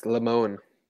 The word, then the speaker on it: Lemonis: Should we still use lemon themed social media posts for Chris Lemonis, even though the lemon part of Lemonis is pronounced lemon? It's, it's Lamone.